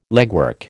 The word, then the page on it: legwork: American English